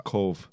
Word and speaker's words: Cobh: My sister-in-law, her brother played with Roy Keane for about 2 or 3 months when he was at Cove Ramblers before he went up to do the Irish. – Is it Cove? – Cove. Spelled C-O-B-H. – Did you say Cove?